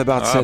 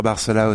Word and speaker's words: Barcella: À l'italienne ce serait Barcella, Barcella. Ça fait très plaisir à ma grand-mère. Et ici, moi, je l'ai francisé, on peut dire Barcella aussi, tout simplement.